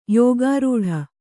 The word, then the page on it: ಯೋಗರೂಢ: yōga rūḍha